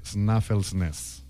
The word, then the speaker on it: Snæfellsnes: És la península de Snæfellsnes.